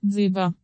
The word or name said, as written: dzyga